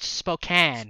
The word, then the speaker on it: Spokane: And it's pronounced Spokane. – Spokane. – It's not Spokane. I learned that today.